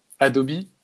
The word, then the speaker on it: Adobe: Adobe, mm-hmm oui, on prononce Adobe en anglais.